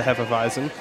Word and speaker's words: Hefeweizen: along with the Hefeweizen, which, by the way, guys, I've been studying up on my pronunciation on that.